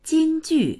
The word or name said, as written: jing1ju4